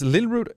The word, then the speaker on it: Lillrud: Is Lillrud, is that how I pronounce this in English, by the way? – Yeah, that's correct.